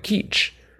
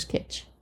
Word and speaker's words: kitsch: I think it's putsch, P-U-T-S-C-H. I'm still trying to figure out how to spell kitsch, how to pronounce kitsch, because I was thinking it was more like keech. – Oh, I thought it was kitsch. But anyway, stayed pooch. – Pooch. – P-U-T-S-C-H.